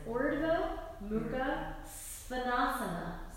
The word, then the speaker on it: Urdhva Mukha Svanasana: I'm just going to pronounce this. Urdhva Mukha Svanasana. – Svanasana. – Svanasana. Svanasana. – Svanasana. – I know it sounds weird, but it's not Shavasana.